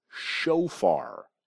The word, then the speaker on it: shofar: So the name of the instrument is the shofar. Shofar. That's spelled S-H-O-F. Like Francis A-R. Shofar.